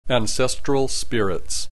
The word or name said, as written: ancestral spirits